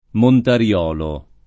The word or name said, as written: Montariolo